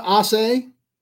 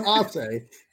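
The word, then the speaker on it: Aase: Don Ossay. – Yes. Well, kids love mispronouncing his name. – They really love saying Don Ossie.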